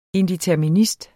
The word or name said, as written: indeterminist